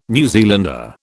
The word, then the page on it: New Zealander: British English